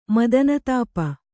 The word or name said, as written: ಮದನತಾಪ